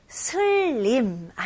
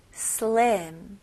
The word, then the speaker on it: slim: 여기도 슬림 아니고요. 이름절로 slim, slim.